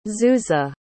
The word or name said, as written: zuza